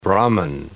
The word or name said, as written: Brahman